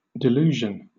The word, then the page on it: delusion: British English